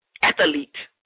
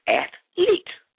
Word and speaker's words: athlete: The incorrect pronunciation of athlete is athlete. You know, you put that A right there in the middle. Athlete. The correct pronunciation of athlete is athlete.